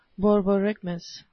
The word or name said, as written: borborygmus